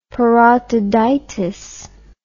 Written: parotiditis

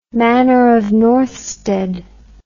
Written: Manor of Northstead

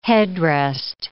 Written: headrest

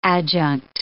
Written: adjunct

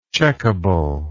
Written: checkability

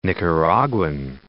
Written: Nicaraguan

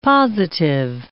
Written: positive

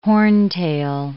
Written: horntail

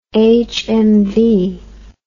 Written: hmv

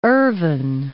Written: Ervine

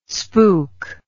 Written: spook

